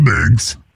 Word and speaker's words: Biggs: Well, my name is Mr. Biggs. That's B-I-G-G-S.